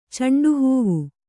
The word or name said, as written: ಚಂಡುಹೂವು